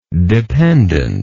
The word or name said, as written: dependently